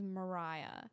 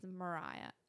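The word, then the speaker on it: Moriah: Yeah, so I go by Riah, just R-I-A-H, but my actual name is Mariah, M-O. – Mm-hmm. Not Mariah Carey. – No, it's pronounced the same way, but it's spelt with an O.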